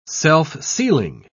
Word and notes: self-sealing: British English

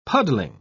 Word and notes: puddling: British English

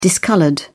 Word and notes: discoloured: British English

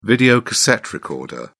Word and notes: videocassette recorder: British English

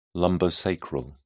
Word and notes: lumbosacral: British English

